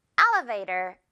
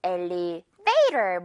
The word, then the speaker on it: elevator: 첫 번째 A블럭에 확 강세 주면서 elevator 이렇게 발음을 해요. 많은 친구들이 elevator 뭐 이런 식으로 조금 다른 곳에 강세를 둬서 영어 원어민들의 귀를 조금 불편하게 할 수가 있거든요. L 부분에 강세 있는 단어라는 거.